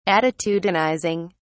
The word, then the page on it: attitudinizing: British English